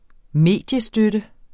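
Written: mediestøtte

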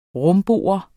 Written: rumbo

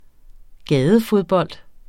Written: gadefodbold

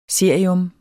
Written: cerium

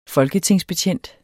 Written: folketingsbetjent

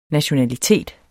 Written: nationalitet

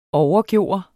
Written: overgøre

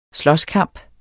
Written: slåskamp

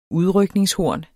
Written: UDRYKNINGSHORNET

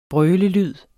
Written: brølelyd

